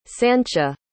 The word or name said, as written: sancha